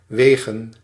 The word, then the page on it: weight: /weɪt/